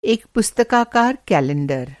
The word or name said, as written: पुस्तकाकार